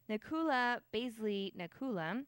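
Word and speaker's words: Nakoula Basseley Nakoula: Nakula Baisley Nakula, I'm almost positive I said that right, the filmmaker who lives in Southern California, was questioned by officials but not detained or arrested.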